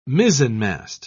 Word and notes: mizenmast: British English

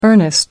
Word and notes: earnest: American English

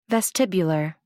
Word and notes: vestibular: American English

